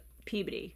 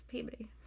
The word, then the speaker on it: Peabody: So, I'm from Massachusetts, and there's a city or a town called Peabody, and we say Peabody. – Peabody. – But then, everywhere else, people say it Peabody. – Peabody, so I'm going to say Peabody, because that's how it's said here. But the correct pronunciation is Peabody.